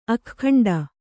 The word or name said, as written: ಅಗ್ಗಂಟು